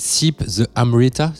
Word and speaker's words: Sip the Amrita: Alors je sais pas comment tu prononces ça, c'est Sip the Amrita, c'est ça? – C'est ça.